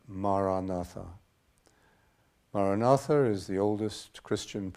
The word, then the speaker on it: Maranatha: If you choose this word, say it as four syllables, ma, ra, na, tha.